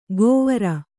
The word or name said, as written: ಗೊವರ